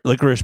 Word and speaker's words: licorice: Like is black or the liquor licorice black? Sorry, I have to say it correctly.